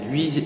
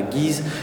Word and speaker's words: Guise: On prononce bien Guise et surtout pas Guise.